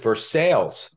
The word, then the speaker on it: Versailles: There's a town called Versailles. It's not Versailles. It's spelled like Versailles, but they pronounce it Versailles. I come down here. I'm like, Versailles? They're like, no, it's Versailles. So I'm like, all right, fine. I'll call it Versailles.